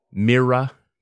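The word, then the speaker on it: Mirra: I settled on a chair called the Herman Miller Mira 2, M-I-R-R-A 2. – I thought that was a pronunciation of M-I-R-R-O-R, the Mira. – Mira. Black Mira. Yeah. No, Mira.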